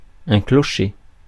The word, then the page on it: limp: American English; IPA: [lɪmp]